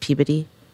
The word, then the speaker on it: Peabody: Before you answer, if Greg is from Massachusetts, it's pronounced Peabody. – Wait, okay. What? – Say that again. – Say that. That was fast. – What, Peabody? – Peabody.